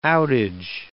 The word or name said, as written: outage